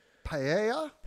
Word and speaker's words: paella: I do not pronounce the L. So you pronounce the D, and you don't pronounce paella. – The in soldo, and you don't pronounce the L's in paella.